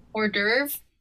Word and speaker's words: Hors d'oeuvre: It's something you make in elementary school, but like I use that as an adorb. I can never say adorb. – I can never say the word right. How do you say it? – Hors d'oeuvre. – Yes.